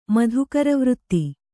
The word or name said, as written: ಮಾಧೂಕರವೃತ್ತಿ